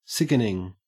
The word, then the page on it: sickening: Australian English